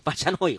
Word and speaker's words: Pachanoi: En esa colección de apodos no podían faltar los botánicos que acabaron por bautizarlo Trichocerus Pachonui. Pachanoi, joder. Joder, no. Joder la pronunciación, decía yo. Pronunciado Trichocereus Pachanoi.